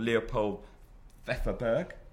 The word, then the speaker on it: Leopold Pfefferberg: correct me if I pronounce it wrong, called Leopold Pfefferberg. – Yes, I've got it right.